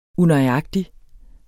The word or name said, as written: unøjagtig